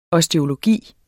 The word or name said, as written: osteologi